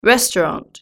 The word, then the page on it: restaurant: British English